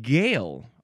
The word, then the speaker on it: Gael: Gale. I think that is how this one is pronounced. Gale, capital G-A-E-L.